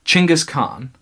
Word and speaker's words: Chinggis Khan: So it was during this time that he gained the name, and this is how it's pronounced differently than we've been pronouncing it. – Okay. – I believe it's Chinggis Khan.